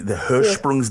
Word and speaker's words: Hirschsprung's: the Hirschsprung's disease.